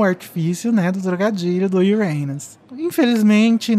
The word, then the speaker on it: Uranus: Porque em inglês, caso vocês não saibam, a pronúncia do nome deste planeta é Uranus. E o que isso lembra? Literalmente, seu cu.